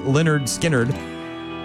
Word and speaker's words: Lynyrd Skynyrd: 53 years ago today, 1973, a little band called Lynyrd Skynyrd released their self-titled debut album, pronounced Lynyrd Skynyrd, featuring the classic jams Gimme Three Steps, Simple Man, Tuesday's Gone, and Freebird.